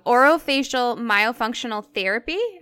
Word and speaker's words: orofacial myofunctional therapy: And you also specialize in, and I'm probably going to butcher this as well, so correct me where I'm wrong, orofacial myofunctional therapy.